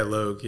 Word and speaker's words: Logue: Yeah, Logue. Yeah. – Awesome. – Like man. Rogue, but with an L.